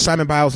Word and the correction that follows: Simone Biles: name. I think I said Simone Biles. – Oh, I just did it again. Simone Biles. That's her name.